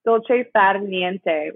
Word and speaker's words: dolce far niente: It's dolce nad fiente. Oh, wait, sorry. I butchered that. Dolce. My accent is terrible. Dolce far niente, which means the sweetness of doing nothing.